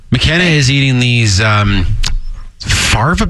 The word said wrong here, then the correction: fava: beans, they're called, or something? – Fava beans. – Is that how you say it?